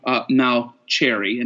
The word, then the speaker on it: Mauceri: John Mauceri, and now I say it right, the French, the Italian way of saying it.